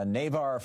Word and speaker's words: Navarre: John, in Navarre, Florida, am I saying that right, N-A-V-A-R? What's up, John? – Yeah, that's correct, Tom.